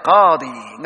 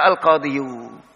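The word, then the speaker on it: qadi (القاضي): Kanal qadi, nggak bisa al qadiyu.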